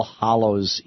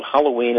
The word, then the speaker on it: Hallows: Well, people always mispronounce it in America. They call it Halloween, and I go, no, sorry, there's nothing hollow about it. It's hallow.